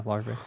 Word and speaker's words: larvae: The larvae, is that how you say it? – Yeah, larvae.